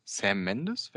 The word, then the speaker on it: Sam Mendes: Es ist ein Kriegsfilm von Sam Mendes, wenn ich es richtig ausspreche.